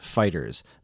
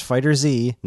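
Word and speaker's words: FighterZ: We can confirm officially that we pronounce it like the word Fighters, not Fighter Z, not Fighter Z, not Fighter Zeto, not Fighters. Fighters.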